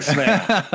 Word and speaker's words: Sverre: I swear it was King Sverre. I think that's how you pronounce it. It's S-V-E-R-R-E. – Yeah, it sounds good.